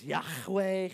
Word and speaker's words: Yahweh: Now, if you're a Hebrew, you would probably say it like this. Yahweh. You got to do the in there.